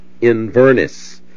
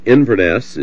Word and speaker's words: Inverness: In Mississippi, we have a town that is spelt just like Inverness, but pronounced by the Mississippians as Inverness, I think is the way they say it. But Inverness is the original pronunciation in Scotland, and you have been at Smithton Culloden since, what, about 1984, David? – Yeah, 83.